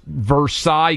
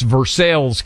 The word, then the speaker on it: Versailles: But if you pronounce Versailles, Kentucky, you will be absolutely ridiculed to the height of, and they'll have no idea what you're talking about. It's Versailles, Kentucky. Versailles is the palace, even though it was named after the palace Versailles.